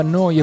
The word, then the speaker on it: Neu: Si scrive Neu, si pronuncia Noi e vuol dire nuova, nuova emittente urbana.